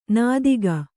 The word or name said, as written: ನೀತಿಕೆ